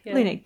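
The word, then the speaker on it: Leunig: But for people who are listening next year, back in October when we're recording, very popular Australia cartoonist, I probably can't pronounce his name, Michael Lunick? – Lunick, yeah.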